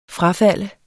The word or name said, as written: frafald